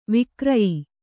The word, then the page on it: ವಿಕ್ರಯಿ: vikrayi